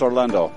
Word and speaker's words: Orlando: My name is Josh, J-O-S-H. – Where are you from? – Houston, Texas. – My name is Orlando. – Orlando, can you spell that? – O-R-L-A-N-D-O.